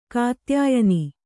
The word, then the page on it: ಕಾತ್ಯಾಯನಿ: kannada